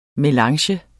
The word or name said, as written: melange